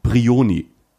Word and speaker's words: Brioni: Die Insel heißt Brioni. Und es gibt noch, das ist die, die italienische Aussprache,